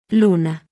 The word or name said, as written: l